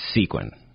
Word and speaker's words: sequin: It's like a penguin, but sequin. – But not like a penguin. – Like a sneaky penguin, sequin.